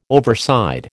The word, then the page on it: overside: American English